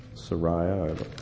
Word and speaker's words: Sariah: Maybe I'm wrong, but Sarah, the wife of Lehi, Sariah, which how you pronounce her name for sure, she was really wrought up when her sons went back to get the plates.